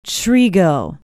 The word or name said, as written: trego